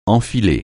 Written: enfiler

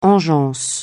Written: engeance

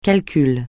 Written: calcul

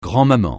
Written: grand-maman

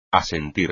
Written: asentir

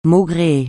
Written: maugréer

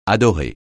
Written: adorer

